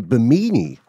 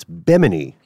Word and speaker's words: Bimini: I'm pronouncing that carefully because for years we've been saying Bimini, like bikini, but it's Bimini, mm-hmm.